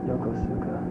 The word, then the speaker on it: Yokosuka: Y-O-K-U-S-A? – Y-O-K-U-S-A? – It's Yokosuka. Y-O-K-O-S-U-K-A. – I've been having problems with some of these Oriental. – Y-O-K-O-S-U-K-A, that's right.